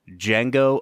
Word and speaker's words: Django: And this week we are looking at the 2012 film Django Unchained. – The D is silent.